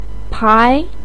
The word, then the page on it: pai2: pinyin